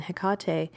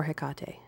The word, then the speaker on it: Hecate: and yes, that is my pronunciation, not most people's. for Hecate, as opposed to Hecate. It's a personal inspired thing. Don't try and analyze it. There is no point. It just is the way I say her name.